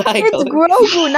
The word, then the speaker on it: Grogu: I guess like the only person he ever cared about was Goku. So that's all. – I'm sorry, did you say Goku? – Is that not how it's pronounced? – It's Grogu, not Goku. – G-R-O-G-U.